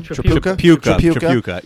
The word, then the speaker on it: Tripucka: Tripucka.